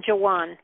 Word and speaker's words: Joän: So it's been Joan. I spell it J-O-A-N, but I put two dots above the A.